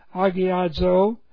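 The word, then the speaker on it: agiazo (ἁγιάζω): Agiazo is the Greek word, A-G-I-A-Z, long O, agiazo.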